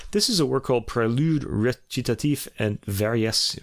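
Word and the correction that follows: Variations: I'm saying this wrong, God. Prélude Recitatif et Variation.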